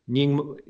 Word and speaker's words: Nyingma: who is associated with the Nyingma Pa tradition, if I'm pronouncing that correctly.